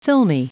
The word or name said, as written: filmy